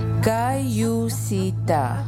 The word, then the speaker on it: Kayusita: Kayusita means let us move forward, and I want to teach you how to pronounce it. Kayusita.